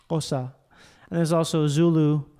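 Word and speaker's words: Xhosa: So there's people who are from a group called Tkosa. Which that's how you say it. It's Tkosa. – Tkosa. – Or it's not, I think it's not a click.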